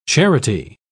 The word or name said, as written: charity